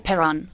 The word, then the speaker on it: Perron: How do I pronounce it? – You just call it Perron.